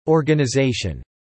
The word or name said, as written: organisation